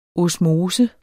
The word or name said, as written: osmose